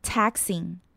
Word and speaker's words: taxing: 接下来我们来看到 taxing，注意到 taxing 的 A 发音是扁扁的音， A taxing，字尾的 xing 会有点 k 跟 s 连起来的音， taxing。